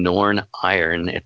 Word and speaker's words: Norn Iron: I've lived in Norn Iron.